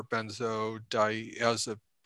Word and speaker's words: benzodiazepine: Benzos being, you know, shorthand for benzodiazepine. Yeah. I never pronounced that correctly. – I think that's like correct.